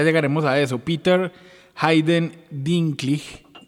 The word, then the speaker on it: Dinklage: No, yo le voy a decir Dinklage toda la vida, pero vamos a intentar. – vamos, a intentar mirar que la, pronunciación supuestamente es Dinkley.